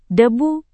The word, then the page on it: debu: Indonesian